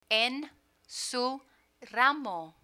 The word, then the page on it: en su ramo: Spanish